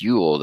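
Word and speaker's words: Jól: So the book is called Yule, issue one, but it's spelled J-O-L with the double dots over the O, and it's pronounced Yule. That's right? – Yes, correct. That's correct.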